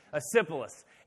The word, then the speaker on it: Asclepius: I'm going to butcher the name. Asclepius. None of y'all knew it anyway, so I didn't butcher the name. That's exactly how it's pronounced. Asclepius.